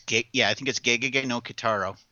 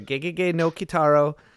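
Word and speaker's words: Gegege no Kitarō: It was shown as part of a triple feature along with, hang on a second here, it was shown with Gege or Gigi, I'm not 100% sure, Gege no Gitaro. – I think it's Gege no Kitaro. – Yeah, Gege no Kitaro.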